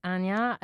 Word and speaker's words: Anna: Well, my name is Anya, but you spell it Anna Leszczyńska.